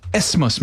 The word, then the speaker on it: isthmus: Is it an isthmus? – Yep. – It's an isthmus.